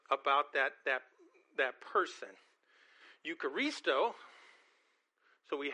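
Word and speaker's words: eucharisto (εὐχαριστῶ): The word thanksgiving in the Bible is eucharisto. And if we break it down, there's elements of that word that you're already familiar with. The E-U is pronounced you.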